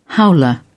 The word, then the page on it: howler: British English